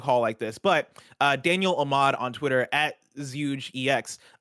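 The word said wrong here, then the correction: ZhugeEX: tweeted out. – Okay. – JugeEX, thank you. I knew I mispronounced it, but I couldn't remember the actual thing.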